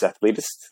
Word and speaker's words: Athletist: So you've created the brand, it's Athletist, that's how you pronounce it.